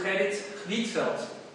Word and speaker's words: Gerrit Rietveld: É, um outro projeto nessa linha também, mas um pouco mais flexível, é a Casa Schroeder do Gerrit Rietveld. Para quem não sabe, essa é a pronúncia do nome do, do holandês.